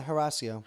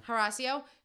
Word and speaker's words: Horacio: Like, okay, so I don't know if you guys caught this, but Nelson literally told Olivia and Horacio to, am I saying his name right? – Yeah, Horacio. – Horacio, to do the same exact thing that everybody freaked out on Thomas and Sis to do.